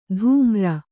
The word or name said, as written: ಧೂಮ್ರ